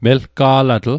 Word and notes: melga'latl: Mi'gmaq

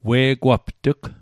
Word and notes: we'gwapt'g: Mi'gmaq

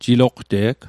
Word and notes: jiloqte'g: Mi'gmaq

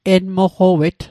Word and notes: enmoqowet: Mi'gmaq

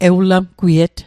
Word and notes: ewlamgwiet: Mi'gmaq

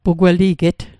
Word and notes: pugweliget: Mi'gmaq

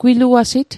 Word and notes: gwiluasit: Mi'gmaq

